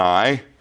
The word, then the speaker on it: Ai: Do you pronounce it AI or do you pronounce it I? And for show of hands, who pronounces it AI? Well, guess what? I pronounce it I now. Because I just figured I'd just be contrary. But you could pronounce it either way, I suppose. But I is how I seem to read it.